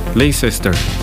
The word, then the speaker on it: Leicester: Un día como hoy, 19 de agosto, pero del año 1951, nacía John Deacon en Leicester, ¿verdad? Así se pronuncia, Reino Unido, bajista de Queen.